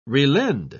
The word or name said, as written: relend